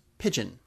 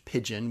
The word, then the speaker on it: pidgin: Okay, I'm going to switch gears for a minute, and I'm going to talk about another of my favorite linguistic rabbit holes, which is pigeon. Now, this is not the kind of pigeon that leaves unpleasant white splotchy messages on your car. I'm talking about the language pidgin, which is spelled P-I-D-G-I-N.